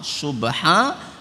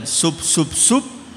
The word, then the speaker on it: Subhanallah: Subhanallah saja bacanya tidak lengkap. Subhanallah. Pripun mause. Nek mboten sub sub sub. Banaloh banaloh banaloh.